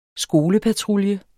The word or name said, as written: skolepatrulje